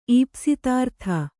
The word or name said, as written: ಈಪ್ಸಿತಾರ್ಥ